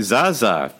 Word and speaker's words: Zaza: Zaza? – The Zaza, the famous Zaza of the greatest performance in Euro 2016.